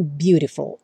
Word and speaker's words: beautiful: O azul claro é quando o T ou o D passam a ter o som rarará, como por exemplo na palavra beautiful, que se escreve com T, seria beautiful, mas a maioria dos americanos falam beautiful.